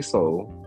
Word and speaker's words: Sol: Now, for the people who don't know, can you let my audience in on who is Saul, and am I pronouncing your name right? – So it's actually Saul.